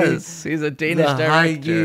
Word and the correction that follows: hygge: It's also, by the way, it's pronounced hygge. So it's not like it's hygge. Hygge.